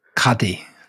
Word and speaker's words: Qadi: And he had been officially named by the Sultan as a, I don't know how to say this, you'll have to explain. Is it a Qadi? A Qadi? Islamic judge? – Yeah. – Is it a Qadi? – Yeah. – You sure? – Yeah. – You're not bluffing? – Qadi.